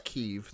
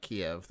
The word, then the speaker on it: Kyiv: And it's Kyiv. It's not Kiev. That's a Russian pronunciation of Kyiv.